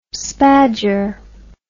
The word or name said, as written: spadger